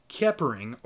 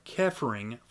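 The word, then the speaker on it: Xepering: Aquino teaches that Setians may become gods through a process known as Zeppering, pronounced Keppering or Keffering from the Egyptian hieroglyphics for to come into being, a striving toward knowledge.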